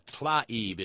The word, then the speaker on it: Tlaib: You probably couldn't say Abu Ghraib either, but it's Rashida Tlaib. It's T-L-A-I-B, not T-A-L-I-B, you goddamned idiot.